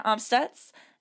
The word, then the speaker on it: Amstutz: I'm Sandra Omstutz, and you can find me on Twitter and Instagram mostly at Sandra Omstutz.